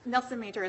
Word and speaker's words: Nelson Major: Nelson Major is my last name, Hayden Nelson Major. – Very good. Thank you. – Good morning, and may it please the court. My name is Hayden Nelson Major.